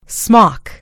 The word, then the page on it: smock: American English